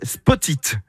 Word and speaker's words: Spoteet: Ça s'appelle Spotit. Spotit, ça s'écrit S-P-O-T-E-E-T.